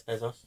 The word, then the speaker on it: Bezos: I would say it's Bezos.